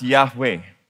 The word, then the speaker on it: Yahweh: So this is pronounced Yahweh.